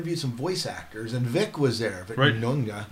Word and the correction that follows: Mignogna: How do you say it, Matt? – Vic Mignogna. – Mignogna. Okay, so yeah, that guy.